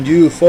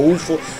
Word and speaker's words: UFO: Ahí escuchábamos un clásico, un infaltable aquí en larga vía rock and roll, como es Doctor Doctor con UFO o UFO, como lo quieran pronunciar ustedes.